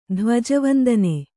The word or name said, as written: ಧ್ವಜವಂದನೆ